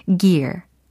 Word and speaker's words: gear: gear, gear. 혹시 gear 이렇게 연습하십니까? gear? gear 아니요. 이런 충청도 사투리에 빠지시면 안 되고 여기서는 gear이 되겠습니다.